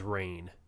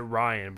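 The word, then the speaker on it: Rainn: I've heard people pronounce it Ryan, but I think it's Rain. I think he's Rain Wilson.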